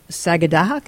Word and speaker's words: Sagadahoc: Sagadahoc, is that how that's pronounced? – Right, that's correct.